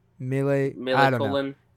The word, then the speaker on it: Millencolin: Is it Millencolin? – Millencolin.